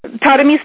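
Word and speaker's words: Tarumi: First, Tadami's name? – How do you spell her name?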